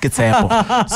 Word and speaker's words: Tsepo: Like Tsepo, I'm like, guys, it's Tsepo.